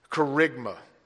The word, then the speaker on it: kerygma: It is the word kerygma. Let me spell it for you. K-E-R-Y-G-M-A. K-E-R-Y-G-M-A.